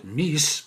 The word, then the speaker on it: MECE: One popular framework used in case interviews is MECE, or as Barbara Minto, who created it, pronounces it MIS, but you, you pick the way that you're more comfortable with.